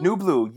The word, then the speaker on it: Nublu: At, you invited me to come to, I don't know how to pronounce it, Nublu? – Nublu, yes, yes.